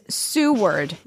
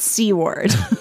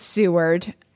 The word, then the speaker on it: Seward: So this is actually a listener via Instagram, and it's, well, one listener wrote in and said, I don't know, but C-word is Seward, not C-word.